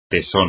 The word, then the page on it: tesón: espagnol